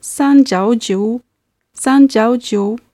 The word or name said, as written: sanjiaojiu